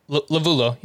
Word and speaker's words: Lovullo: Lovullo.